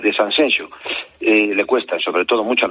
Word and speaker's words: Sanxenxo: No, Eduardo, que no, que es Sanxenxo.